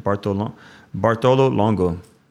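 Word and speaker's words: Bartolo Longo: How do you say his name? Bartolongo. Yeah. So blessed Bartolongo, pray for us.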